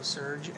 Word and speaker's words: Serge: It's the first four letters of my name, so Serge, S-E-R-G, and then my whole last name, Panero. For both Instagram and Twitter, Serge Panero. – Can you spell that for everyone? – So S-E-R-G-P-I-N-H-E-I-R-O. Just the same.